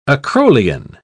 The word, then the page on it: acrolein: British English